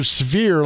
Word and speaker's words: sphere: All right, we'll get back to our question of the day, which is there are grumblings that they're going to build another sphere like the one in Vegas. Why did I say it like that? Sphere. There we go. Like severe. Like Mrs. Wiggins.